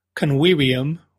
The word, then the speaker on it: convivium: And she wrote in and said, ad proximum convivium, which the last word is pronounced with W's, but both of those are V's.